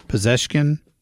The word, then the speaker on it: Pezeshkian: I'm not quite sure why he didn't have the Ayatollah Khomeini on there, but that was Masoud Pezeshkian. Am I pronouncing that right? – Sure. – Pezeshkian? – Yeah.